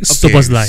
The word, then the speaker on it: Szoboszlai: Your Liverpool guy that I cannot pronounce, Zibo Jelai. – Okay, his name is Dominic Szoboszlai.